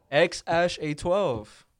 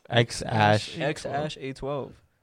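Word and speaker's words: X Æ A-12: X-Ash-A-12. That's how you pronounce it. – X-Ash-A-12.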